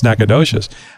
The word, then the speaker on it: Nacogdoches: But being in, uh, in Southeast Texas all my life, I, I know it's Nacogdoches.